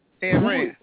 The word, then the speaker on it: Ayn Rand: I want to be Ayn Rand or whatever, however you pronounce it. – Ayn Rand.